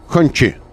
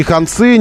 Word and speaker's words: Хончи: Хончи. А, нужно, нужно очень привыкнуть произносить это все дело. Но, но не ханцы, не концы, не ханцы, не хунцы никакие. Хончи. А, вот так.